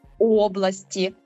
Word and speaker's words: області: «Області», «давальний», «областями», «знахідний», «області», «кличний», «області». Тут правило досить конкретне і ніяких розбіжностей немає абсолютно ніде. Тільки родовий відмінок областей, все інші слова ми ставимо наголос на першу «о».